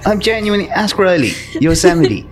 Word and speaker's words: Yosemite: Do you know the little guy with the guns in Looney Tunes? – Yeah. – What's his name? – I have no idea. – Yosemite Sam.